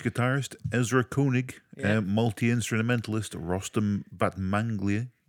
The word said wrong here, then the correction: Batmanglij: Stop. – I can't pronounce that. – Say that again. – Rostam Batmanglij. – Rostam. I'm not fussed about the surname. – Rostam. Well, it's Batmanglij.